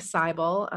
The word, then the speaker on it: Seibel: Um, so my name is Natalie Seibel.